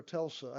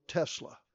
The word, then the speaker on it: Tesla: You know, I have people say, "Oh, you got to go get a, was it a Tesla or a Telsa?" I never can pronounce it right. What is it? – Tesla.